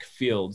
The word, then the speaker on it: Feild: And then my name is Shadrach Field. So Shadrach, and then Field is spelled Feild.